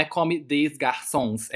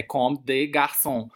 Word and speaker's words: Comme des Garçons: A música aí da Rina Sawayama e a grife de moda, é tem um S ali que não é pronunciado, né? Não é come des garçons, é come de garçon Então, é, a consoante é muda.